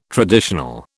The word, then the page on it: traditional: British English